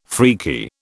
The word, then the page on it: freaky: British English